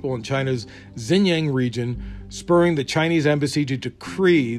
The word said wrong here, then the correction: decry: the move as interference in international affairs. Decry. Look, let's go with decry. I think that's the proper pronunciation.